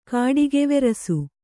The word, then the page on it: ಕಾಡಿಗೆವೆರಸು: kannada